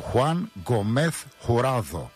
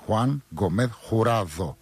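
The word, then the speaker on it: Juan Gómez-Jurado: Είναι Ιουλή του Χουάν Γκομέθ Χουράδο. Προσέξτε για την προφορά, έτσι. Χουάν Γκομέθ Χουράδο. Η Ιουλή είναι ένα εξαιρετικό πραγματικά βιβλίο, το οποίο δεν είναι απλά αστυνομικό βιβλίο.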